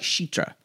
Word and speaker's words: Citra: I believe it's, she says it like Shitra. – Oh, okay. Whatever. – Shitra, with an S-H.